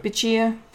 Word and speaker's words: Bichir: I'm going to botch the pronunciation of this guy's name, Damien Bichir. I don't know. – Bichir.